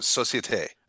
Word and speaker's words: Société: And I don't know if you know this brewery, but the name is spelled S-O-C-I-E-T-E. How would you say that? – I would say the French way, which is also the way they say it at the brewery, which is Société.